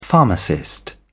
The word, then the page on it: pharmacist: British English